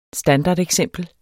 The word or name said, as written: standardeksempel